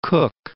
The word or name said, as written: Northern Cook Islands